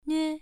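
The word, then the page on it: nue: tone 1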